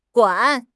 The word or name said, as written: guan3